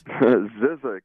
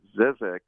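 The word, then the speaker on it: Zzyzx: You'll see the sign and you're like, you know, how the hell do I pronounce that? Yes, you pronounce it Zizek's, and it's a song that was off of the Stone Sour album, Come Whatever May.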